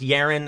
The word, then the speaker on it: Yeren: I think it's pronounced Yeren. That seems to be the most common pronunciation that I can find.